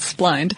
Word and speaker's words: splined: They're splined, spleened. Spleened is probably not the correct pronunciation there. I'm going to assume it's splined directly to the shaft.